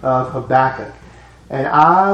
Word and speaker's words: Habakkuk: Most people pronounce his name Habakkuk.